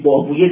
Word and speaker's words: بابویه: پسوند اویه پسوند پارسی است. قولویه، شیرویه، سیبویه، راهویه، خالویه، بابویه، اینا رو در تلفظ عربی ویه میخونیم، در تلفظ فارسی اویه باید بخونیم، به معنای رایحه و به معنای ماننده.